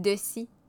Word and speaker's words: Dessy: Françoise Dessy. Françoise, vous me corrigerez si je... – Dessy.